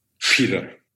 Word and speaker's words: PETA: I think most people, because everyone thinks when they think environmental, Greenpeace and PETA and whatever. And actually, you've worked with them. I haven't worked with them. So you say PETA. Do they say PETA or PETA? – PETA.